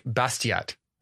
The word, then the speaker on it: Bastiat: All right, and our quote of the day is from Frederick Bastiat. Is that how you pronounce that? – It is how you pronounce that.